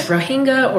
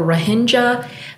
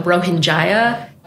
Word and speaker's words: Rohingya: Everybody pronounces it differently. So it's Rohingya or Rohingya. Some people call it the Rohingya, which I used to call it the Rohingya, but that's way too many syllables. So it's not convenient. So I would recommend against that one.